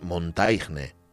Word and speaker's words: Montaigne: Y lo pronunciamos con nuestro francés del, llano, pero para quien no lo mm. conozca, es Montaigne, ¿vale? Michel de Montaigne.